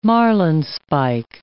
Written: marlinespike

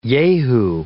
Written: yahoo

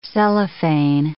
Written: cellophane

